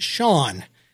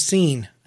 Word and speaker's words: Sean: That is because I only had read the name in books, and nobody had explained to me that it was actually pronounced Sean.